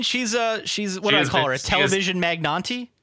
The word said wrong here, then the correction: magnate: Oh, he meant magnate. – I think he means magnate, but I don't know.